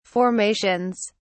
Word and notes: formations: British English

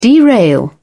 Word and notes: derail: British English

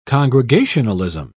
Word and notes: congregationalism: British English

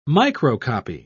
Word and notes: microcopy: British English

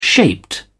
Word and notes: shaped: British English

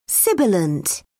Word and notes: sibilant: British English

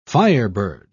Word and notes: firebird: British English

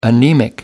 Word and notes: anemic: American English

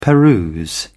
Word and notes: peruse: British English

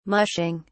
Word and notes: mushing: British English

